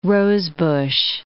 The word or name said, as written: rosebush